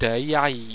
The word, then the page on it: deiaei: Owstwecian